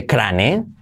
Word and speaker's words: crane: Una grúa, se dice crane. Escrito y se pronunciaría mal pronunciado crane, pero con la R y la U. Crane. Crane. Crane. ¿Ves? La R siempre genera una U extra después.